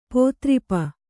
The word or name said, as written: ಪೋತ್ರಿಪ